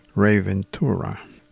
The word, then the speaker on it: Ray Ventura: I guess I will pronounce the name of the band leader as they would in English, Ray Ventura. It's Ray Ventura.